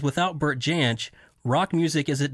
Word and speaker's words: Jansch: Am I saying that right, Bert Jansch? – Yeah, that's correct.